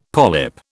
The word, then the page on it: polyp: British English